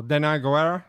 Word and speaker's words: Danai Gurira: What's how do you pronounce Michonne's name? – Oh, Danai Gurira.